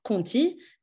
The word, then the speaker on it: Conti: Je suis Fabiola Conti. D'ailleurs, tu as très, très bien euh, prononcé mon nom de famille qui vient de l'Italie, comme moi d'ailleurs.